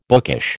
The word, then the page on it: bookish: American English